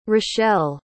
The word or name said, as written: rachelle